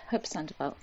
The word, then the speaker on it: Hope Sandoval: Hope Sandoval? – Yes, right, yeah, Hope Sandoval.